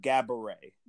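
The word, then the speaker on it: Gabourey: Gabby Ray.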